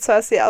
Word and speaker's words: Saoirse: Ja, es wird Cersei ausgesprochen.